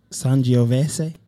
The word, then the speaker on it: Sangiovese: 50% Sangiovese.